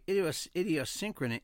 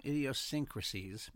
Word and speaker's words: idiosyncrasies: While facial attractiveness is something punctuated by idiosyncrasies, mm-hmm. Oh my God, I'm saying it wrong, idiosyncrasies, mm-hmm.